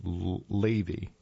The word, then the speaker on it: Lev: Lev, L-E-V. It's pronounced Levi, Lev. Pronounced Lev, L-A-V. L-E-V is the word, but it's pronounced as L-A-V-E, Lev, which is the typical underlying Hebrew word for the heart in the Old Testament.